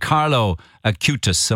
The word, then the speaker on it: Carlo Acutis: Tell us about St. Carlo Acutis.